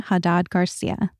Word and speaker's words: Jadad-García: So with that, please enjoy Tamen Jadad-García.